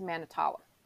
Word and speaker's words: Manitowoc: Yeah, I went as soon as like I heard about the fire and I saw the ash pile, my mind went straight to Manitowoc. Is that how you say it? – Yes.